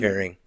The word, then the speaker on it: Turing: We want to give our flowers to Alan Turney, Harvey. – Turing. – Sorry, I'm saying his name wrong.